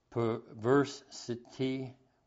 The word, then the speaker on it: perversity: When God saw his perversitive, perversity. Am I saying it right? – Perversity. – Thank you.